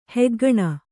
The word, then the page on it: ಹೆಗ್ಗಣ್: heggaṇ